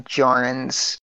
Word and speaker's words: Djarin: It's actually now coming to my attention just because you pointed out that Din Djarin's last name having a silent D for a J is definitely like a Django reference.